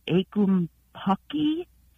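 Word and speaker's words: ackumpucky: I think you would pronounce it akumpaki.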